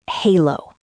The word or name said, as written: halo